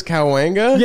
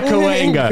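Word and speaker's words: Cahuenga: was it North Cowahunga? How do you say that, bro? – I think it's Cowanga. – Yeah, Cowanga. Cowanga.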